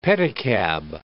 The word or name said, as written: pedicab